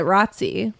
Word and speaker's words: ROTC: They don't call it that though. They call it ROTC. – That's true. – Yeah, it rhymes with Nazi.